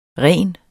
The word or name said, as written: rens